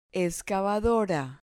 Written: excavadora

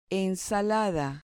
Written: ensalada

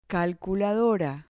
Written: calculadora

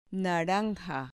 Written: naranja

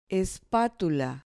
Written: espatula

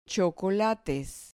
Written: chocolates

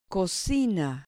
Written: cocina

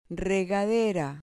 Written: regadera

